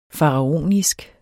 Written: faraonisk

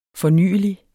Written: fornyelig

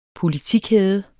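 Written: politikæder

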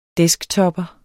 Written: desktopper

